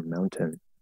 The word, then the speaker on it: mountain: Um, it should be a schwa. If It's not just a straight out. Yeah, they, they just do as a straight out syllabic consonant. consonant. Um, the full pronunciation of this word would be mountain, mountain.